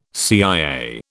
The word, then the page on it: CIA: British English